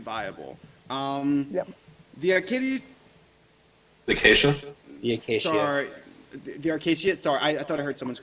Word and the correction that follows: The Acacia Strain: Did I pronounce that wrong? – The Acacia Strain. Yes. You're good. – The Acacia Strain.